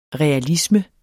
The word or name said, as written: realisme